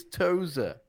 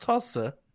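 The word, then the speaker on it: Tosa: The corner, the hairpin at the top of the circuit is Toza, not Tossa.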